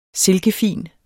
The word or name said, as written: silkefin